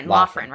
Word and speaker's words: Loughran: Jonathan Lurin. That's how you say it, right? – Loughran, right? – Yeah.